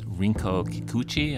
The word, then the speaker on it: Rinko Kikuchi: The actress who plays Kumiko, her name is Rinko Kikuchi.